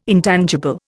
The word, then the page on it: intangible: Indian